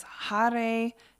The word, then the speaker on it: Hare: And it's Hare, which is H-A-R-E, and I pronounce it with a soft R.